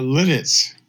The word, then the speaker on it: Lititz: How do you say that? – Lititz. – Lititz. Okay, I knew I was going to say it wrong.